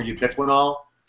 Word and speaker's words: ubiquinol: So it's called ubiquinol, and I believe it's spelled U-B-I-Q-U-I-N-O-L? – Yes. – Ubiquinol. That's right.